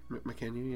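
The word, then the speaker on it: Mackenyu: Okay, the kid actor for Zoro is literally the spin image of Makenyu. Did I say that right? – Makenyu, yeah.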